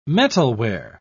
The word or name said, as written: metalware